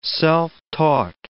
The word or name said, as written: self-taught